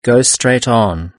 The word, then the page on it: go straight on: British English